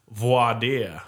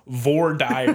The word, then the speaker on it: voir dire: That is a hard border between people that say it right, voir dire. And people that say it like Philistines, vor dire.